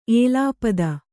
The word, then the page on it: ಏಲಾಪದ: kannada